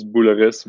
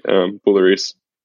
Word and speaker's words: Bolloris: It's Jeremy, in French it's Bolloris, but English people would say Bolloris.